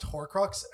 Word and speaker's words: Horcrux: It's pronounced Horcrux, and yes, I could, but that's because I read all the books.